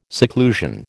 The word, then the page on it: seclusion: American English